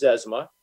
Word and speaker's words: Zezima: it, it is Zezima.